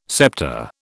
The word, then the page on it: sceptre: British English